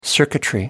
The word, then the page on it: circuitry: American English